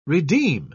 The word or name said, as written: redeem